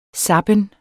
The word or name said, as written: zappen